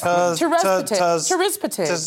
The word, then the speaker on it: tirzepatide: The highest dose of the weekly injection, known as tirzepatide. – I'm glad you tried to pronounce that, because I think... – Yes, tirzepatide.